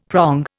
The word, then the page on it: prong: Indian